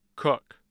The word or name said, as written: cook